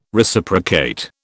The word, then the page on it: Reciprocate: British English